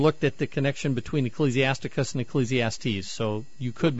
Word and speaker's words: Ecclesiasticus: I'm wondering if maybe you're talking about Ecclesiasticus. – Ah, yes, that is how it's said.